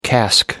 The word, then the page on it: cask: American English